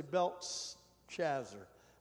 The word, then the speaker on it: Belteshazzar: You have to say Belteshazzar on that. Belteshazzar, that was Daniel. Hananiah, he called Shadrach. S-H-A-D-R-A, it's actually Shadrach is how you say it.